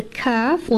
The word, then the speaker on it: kaf: Kaf is pronounced from here. When the back portion of the tongue touches the hard palate above, not as far back as the qaf.